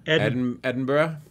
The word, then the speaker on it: Edinburgh: Edinburgh. – Edinburgh. – Yeah. – Edinburgh is Burra.